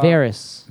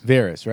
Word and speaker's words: Varys: How do you pronounce the bald guy's name? Varys? – Oh, wow. – Varys. – Varys, right? – Okay. Yep.